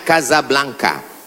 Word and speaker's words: Casablanca: Ibu kota Maroko, tempat saya kuliah, nama negaranya Maroko, ibu kotanya tulisannya Casablanca, cara bacanya Casablanca.